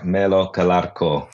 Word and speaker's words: Colaco: Oh, with an Italian accent, it's more like Melo Colaco.